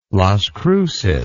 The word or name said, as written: Las Cruces